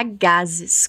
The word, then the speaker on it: gases: E ela diz que não gosta porque dá gases. Como ela diz isso? Muito parecido mesmo. Só toma cuidado com essa pronúncia do S, que soa como os dois S's do português. Gases.